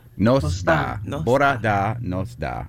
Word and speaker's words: bora da: Good morning is bora da.